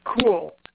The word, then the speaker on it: Kule: My last name is pronounced Kule. It's K-U-L-E.